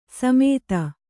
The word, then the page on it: ಸಮೆತ: sameta